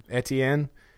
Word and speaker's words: Etienne: Both of those guys are averaging over 7 a carry, and Etienne, is that how you say it? – Etienne.